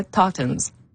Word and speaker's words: Touton: Comment on dit ça, le Toutons? – Donc, en anglais, vous avez bien réussi Touton.